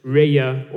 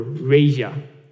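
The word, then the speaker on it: Reya (רֵעַ): In the Strong's Dictionary, the Hebrew equivalent is found under H7453, and is pronounced Reya or Reja.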